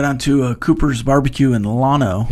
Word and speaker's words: Llano: The Texas town Llano is pronounced with the L sound, and they get very upset when you use the Y sound. Llano, Texas.